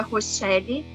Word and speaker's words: Rochelle: Eu tenho uma página, Pedagoga Rochelle, com C-H-E, dois l i E.